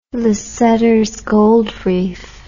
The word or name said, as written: Lasseters Gold Reef